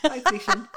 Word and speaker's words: Diocletian: Diocletian. I'm not even sure if I got that right. – Yeah. – I got the guy right. I'm not sure I said his name right. – Yeah, Diocletian. Yeah.